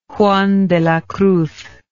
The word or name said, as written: Juan de la Cruz